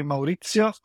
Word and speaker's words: Maurizio: The name is actually Maurizio Affone.